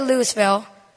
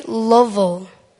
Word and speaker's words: Louisville: There's Some people say Louisville. Some people say Louisville. Well, it's actually Louisville. Louisville. That's how they say it.